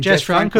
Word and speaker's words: Jess Franco: Jess Franco, the Spanish.